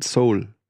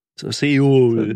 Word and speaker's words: Seoul: Ist halt kein Soul. Ist halt kein Soul. Seoul.